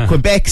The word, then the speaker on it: Quebec: Quebec.